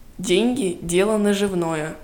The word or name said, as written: деньги дело наживное